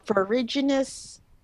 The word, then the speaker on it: ferruginous: It sounds correct to me because it sounds like forage, like foraging for stuff. So ferruginous hawks.